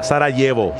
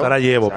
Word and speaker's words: Sarajevo: Vos sabés que se pronuncia Sarajevo. – Sarajevo, claro. – Sarajevo, pero se lee castellanizado como correctamente hizo GPN.